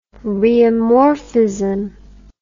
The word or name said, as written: rheomorphism